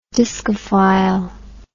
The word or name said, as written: diskophile